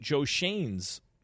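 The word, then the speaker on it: Joe Schoen: Brian Daboll is very high on the Giants GM candidate, Joe Shane's.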